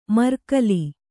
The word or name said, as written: ಮರ್ಕಲಿ